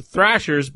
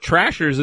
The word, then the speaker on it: Thrashers: It's Thrashers, but Trashers isn't a bad name for them because they were terrible.